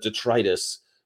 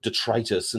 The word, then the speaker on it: detritus: For years, I pronounced it detritus instead of detritus. For years, I did that. I was probably 25 before I knew that it was pronounced detritus instead of detritus.